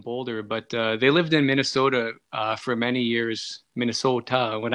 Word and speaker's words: Minnesota: So just so everybody knows, geographically, you're in Minnesota, in the great state of Minnesota. – You didn't quite say it right. It's Minnesota.